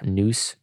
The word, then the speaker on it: Nous: Nous Church. Is that how you pronounce it? – Yep. – Is it? Okay, it's the Greek term, I guess.